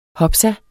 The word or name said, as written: hopsa